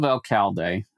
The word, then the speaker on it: Alcalde: And near the town of Alcalde, and I'm sure I'm mispronouncing that, but you know, whatever.